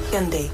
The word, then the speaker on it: Hyundai: Hyundai does not sound like Sunday.